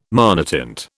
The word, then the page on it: monotint: British English